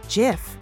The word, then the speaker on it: GIF: And one last note, I know the whole debate over how to pronounce GIF or GIF is ongoing, but for what it's worth, when the inventor, Steve Wilhite, received a Lifetime Achievement Award at the Webbies in 2013, that was also when he attempted to put this debate to rest via an image shown on stage that read, "It's pronounced GIF."